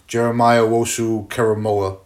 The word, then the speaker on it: Jeremiah Owusu-Koramoah: I mean, you got a guy in JOK from Notre Dame, Jeremiah Owusu-Koramoah, who... – That was great pronunciation.